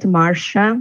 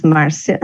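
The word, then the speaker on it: Márcia: Well, first of all, my name is Marcia, and I think in English people pronounce it Marcia, but in Portuguese is Marcia, and I'm from Brazil.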